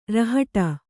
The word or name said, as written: ರಾಹುಟಿ